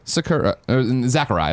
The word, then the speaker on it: Sakurai: Super Smash Brothers Ultimate creator and director, Sakurai, that's how you say it. – Sakurai. – You say it with a Z, though. – No, I don't. – Sakurai. – I don't do that.